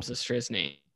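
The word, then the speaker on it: Zastryzny: Cubs legend, Rob Zastrzyzny. – Oof. – R-O-B. – I actually think I can spell it. – Z-A-S Zastrzyzny.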